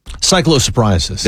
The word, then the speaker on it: cyclosporiasis: Cyclosporiasis? – Cyclosporiasis.